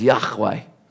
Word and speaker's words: Yahweh: So the translator has said, every time I use this capital L, small caps, O-R-D, it means sovereign God. There's a Hebrew word, if you really want to know, the way Hebrew scholars say it is Yahweh.